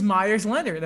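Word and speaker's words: Meyers Leonard: Who is Myers Leonard? Am I saying his name properly? – You are saying his name properly.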